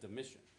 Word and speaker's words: Domitian: Okay, I even Googled this and, and, like played it several times to make sure. I'm like, that sounds like Domitian. Um, but anyway, anyway, that's how you pronounce his name.